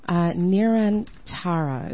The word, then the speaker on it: Nirantara: And as we approach the show, Nirantara, is that how we pronounce it? – Mm-hmm.